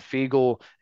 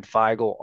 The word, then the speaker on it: Feigl: Then, obviously – a guy I usually start with when we talk about freshmen, but another guy, Ben Feigl, is the way Nick Hill pronounced it. I've been saying Feigl all offseason, so I'm going to take coach on his word on that one.